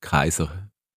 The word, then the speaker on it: Caesar: Und zwar Cäsar, das ist eigentlich richtig ausgesprochen, heisst Kaiser.